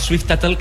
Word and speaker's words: Swift-Tuttle: Aquest cometa, el Swift, com es pronuncia? – Swift-Tuttle, que és, ho coneixies, Marc, el Swift-Tuttle.